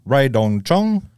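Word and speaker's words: Rae Dawn Chong: Lester and starring Arnold Schwarzenegger, Ray Dong Chong. I'm saying it right. – Yeah, it's good.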